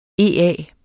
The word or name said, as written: EA